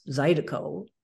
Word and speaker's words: zydeco: The music from Southern Louisiana um, it's the Louisiana Creole pronunciation of les haricots, the French uh, words for beans, the beans. And it apparently comes from a song title written by um, Clifton Chenier. And it's the title, or anyway, it's a line in the song, is something like Les haricots, pronounced zydeco, yeah.